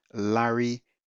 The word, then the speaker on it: Larry: Once you're there, you can type in the titles and search, or you just type my name, Larry Sati. So my first name is spelled L-A-R-R-Y. My second name is spelled S-A-Y-T-E-E.